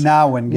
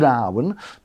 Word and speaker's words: Gnarwin: Let's go on to, how do you pronounce this? – Narwin. – Narwin. – Silent G. – Silent G. It is the first letter, by the way. If you're wondering where that silent G fits, it is the first one. So if it wasn't silent, it would be Gnarwin. But it's not. It's Narwin Road in Carnegie.